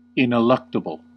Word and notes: ineluctable: American English

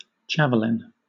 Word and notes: javelin: British English

